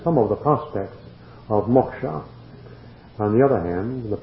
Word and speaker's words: moksha: It is spelled M-O-K-S with a dot below it, or S-H-A, moksha.